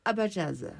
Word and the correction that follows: Abazaï: Abazaï. – Abazaï, voilà.